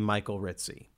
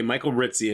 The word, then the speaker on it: Michael Rissi: And they pronounce his name Michael Ritzy. So. – Yeah, this is, it's okay, Michael Ritzy.